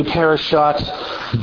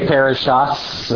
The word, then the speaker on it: parashat: Ashkenazis pronounce the Tav as an S sound, and Sephardic and modern Hebrew pronounce it as a T sound. So they say parashats, and they mean parashat. I'm just pronouncing it the way, if you were speaking in Israel today, that's how I'm speaking, or if you were in a Sephardic community.